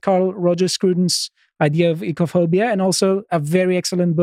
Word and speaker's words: oikophobia: I pronounce it ecophobia. Some people pronounce it oikophobia. You won't get Greeks... modern Greeks, at least, to pronounce it this way, but this is disliking oiks, you know, the people you see.